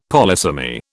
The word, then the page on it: polysemy: British English